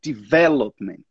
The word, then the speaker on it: development: Aqui, a, a ênfase da pronúncia está um pouco antes, está uma sílaba antes. A gente fala development.